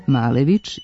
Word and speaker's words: Malević: Piše se Malević i na ruskom i na ukrajinskom, a tako se i izgovara, doduše na ruskom nešto mekše.